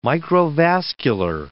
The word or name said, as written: microvascular